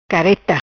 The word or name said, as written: Careta